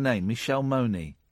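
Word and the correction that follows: Mone: Michelle Moan.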